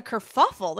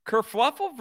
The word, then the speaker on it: kerfuffle: No, it's a kerfuffle. There's no fluffle involved. – There's fluffle involved? Are we sure? Kerfluffle versus kerfuffle. – Kerfluffle certainly is not a word.